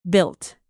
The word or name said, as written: built